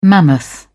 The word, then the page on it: mammoth: British English